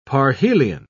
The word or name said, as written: parhelion